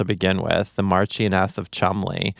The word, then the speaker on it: Cholmondeley: I was told the pronunciation is Cholmondeley, but the spelling of that is like wild and is like going to, you know, is giving me a panic attack just looking at it. – It looks like there are like eight different syllables. – Yeah, Cholmondeley is how it looks. But I've been told it's Cholmondeley. British listeners, correct me if I'm wrong.